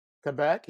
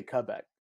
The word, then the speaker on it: Quebec: Quebec? You say Quebec. I think Americans say Quebec because we Q-U very hard, but you say Quebec.